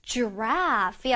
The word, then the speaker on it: giraffe: Giraffe 였지요? Giraffe 할 때 그 두 번째 음절 R-A-F-F-E 부분을 유의하면서 발음하기로 했지요?